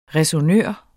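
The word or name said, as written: ræsonnør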